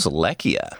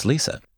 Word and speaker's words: Lecia: Mary has an older sister, Lisa, which is spelled L-E-C-I-A, which shocked me because I read this audiobook, and then I was like, who's Lekia? And I was like, oh, okay, it's Lisa.